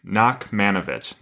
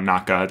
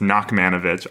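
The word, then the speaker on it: Nachmanovitch: Nachmanovich, not Naka, just Nachmanovich.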